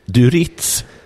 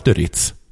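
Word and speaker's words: du Rietz: Vet du vem Kim Ekdahl Duritz, trots att det inte uttalas Duritz, är?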